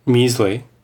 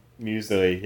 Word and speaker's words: Muesli: how do you pronounce... What's it? Muesli? Is that it? – Muesli. – Muesli, yeah. And I ate that with a cup of coffee on the side.